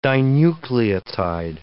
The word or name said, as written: dinucleotide